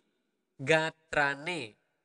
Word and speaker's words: sapunapi gatrane: The very polite way would be sapu napi, sapunapi sapunapi gatrane. That would be again uh, you need to clearly clearly pronounce the R.